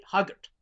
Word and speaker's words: Highgate: I think you're pronouncing it wrong. I've been on the tube there, and the tube pronounces it Huggert.